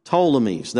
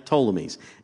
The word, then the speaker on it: Ptolemies: The Ptolemies. That actually starts with a P. The P is silent. Okay, Ptolemies.